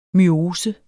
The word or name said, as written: myose